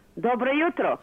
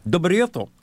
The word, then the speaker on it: Dobro jutro: Dobro jutro. – Dobro jutro.